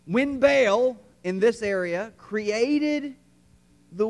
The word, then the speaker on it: Baal: Uh, you remember the name Baal or Baal, if you want to pronounce it correctly. But we don't. I don't want to. I'm in the South. I'll say Baal.